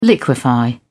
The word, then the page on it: liquify: British English